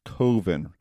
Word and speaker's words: Coven: It's pronounced Coven. Rhymes with oven? As in, I took the cookies out of the oven.